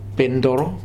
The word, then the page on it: Pentoro: Futuna